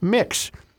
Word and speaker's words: Mx: They often prefer the pronoun they and prefer to use the honorific title of mix, which is pronounced mix.